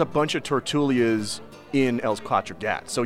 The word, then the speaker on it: Els Quatre Gats: So Picasso spent most of his time in Barcelona at Els Quatre Gats, the Four Cats. And that is the Catalan pronunciation. That's not me butchering basic French and Spanish.